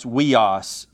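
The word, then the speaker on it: weos (υἱός): So the first word is pronounced weos, and it is literally translated son.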